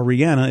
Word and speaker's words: Rihanna: A lot of people getting Rihanna tattoos, or Rihanna, if you want to say her name right.